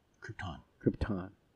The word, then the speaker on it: Krypton: Krypton.